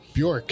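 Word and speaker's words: Björk: How do you pronounce her name? – Bjorg. – Bjork. – Bjork. – Bjork? – Not Bjorg.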